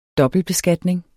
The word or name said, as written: dobbeltbeskatning